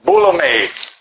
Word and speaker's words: boulomai: And so the word "will" is translated from the Greek verb "bulome." If you want to do your own word study here, it's spelled B-O-U-L-O-M-A-I.